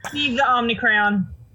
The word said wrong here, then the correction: Omnicran: Crayon or Cran? – Cran. C-R-A-N. – Okay. I bought the Omnicran.